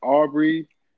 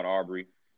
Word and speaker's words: Arbery: I hope I'm pronouncing his last name right, man. Is it Aubrey? – I think it's Ahmaud Aubrey. I've been saying Ahmaud Aubrey, Aubrey you know, Aubrey.